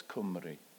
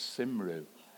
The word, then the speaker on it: Cymru: And I don't know how much you would know of it, but the Welsh word for Wales is Cymru, and I pronounced it up to that point as Simru.